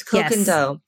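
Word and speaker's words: Koh Gen Do: Do you guys know how to pronounce that? – Is it Kogendo? – Oh, Kogendo. – Yes, Kogendo.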